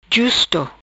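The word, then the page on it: Justo: patua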